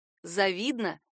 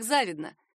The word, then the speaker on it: завидно: Многие произносят его как завидно, хотя верный вариант — завидно. В качестве проверочных тут могут выступать однокоренные глагол «завидовать», прилагательное «завистливый» и существительное «завистник». Во всех этих словах ударение падает на «и», ничего не меняется. Поможет запомнить это ударение и рифма «обидно-завидно».